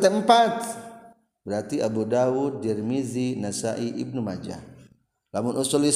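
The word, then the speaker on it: Tirmidhi: Imam Turmuzi lima atau Tirmizi bacanya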